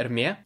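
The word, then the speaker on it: Hermès: Вот, я говорю, есть бренд Эрме, который на английском пишется как эрмес типа Гермес.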